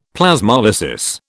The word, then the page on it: Plasmolysis: British English